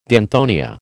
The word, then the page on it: danthonia: American English